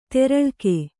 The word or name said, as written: ದೂರಲೇಖ